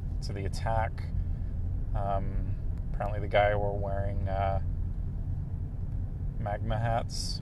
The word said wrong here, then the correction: MAGA: Magma? Is that how you say it? Yeah, magma. MAGA? No, magma is the lava stuff, right? MAGA hats. To be honest, I don't know all of the details of the story, so I'm kind of spewing misinformation.